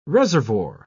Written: reservoir

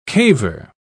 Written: caver

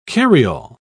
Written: carryall

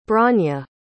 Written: bronya

